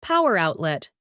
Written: power outlet

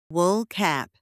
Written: wool cap

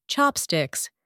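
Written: chopsticks

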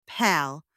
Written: pal

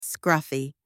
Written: scruffy

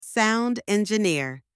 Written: sound engineer

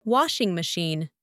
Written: washing machine

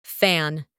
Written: fan

